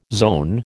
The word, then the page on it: zone: American English